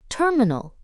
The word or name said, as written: terminal